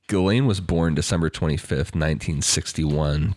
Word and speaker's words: Ghislaine: And then it's pronounced Ghislaine. I like it. – Well, the S is silent.